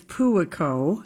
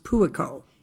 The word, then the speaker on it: Puako: Okay, so what is happening on Kua Kua Pua Pua? – Julia, it's Puako. – Puako. How do you spell it? I literally was looking it up today. – Okay, Puako, and it's pronounced Puako.